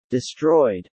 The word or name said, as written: destroyed